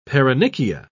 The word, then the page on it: paronychia: British English